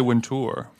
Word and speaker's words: Wintour: I love how you say Wintour. – Well, that's how you say it. It's like Louis Vuitton. Vuitton.